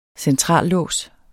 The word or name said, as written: centrallås